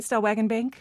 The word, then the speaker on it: Stellwagen Bank: First, take us to Stellwagen Bank. Am I pronouncing that right, Stellwagen Bank? – Yes, you are.